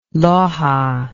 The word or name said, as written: Loja